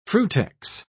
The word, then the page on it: frutex: British English